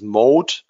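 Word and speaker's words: moat: Im Englischen spricht man, heißt es Mode, also O-A-T.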